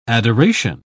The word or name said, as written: adoration